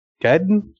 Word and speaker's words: Gaiden: Gaiden? – Yeah, correct.